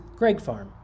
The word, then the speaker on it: Greig: Greg Farm, spelled G-R-E-I-G, is pronounced Greg Farm. Greg Farm. We've been calling it Greg Farm.